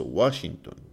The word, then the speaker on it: Washington: Washington, Washington.